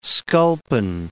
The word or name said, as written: sculpin